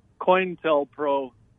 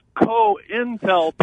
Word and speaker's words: COINTELPRO: I heard you reference in an earlier segment the FBI's erstwhile, what you called COINTELPRO fiasco. And it's actually an acronym that's pronounced COINTELPRO.